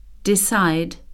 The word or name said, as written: decide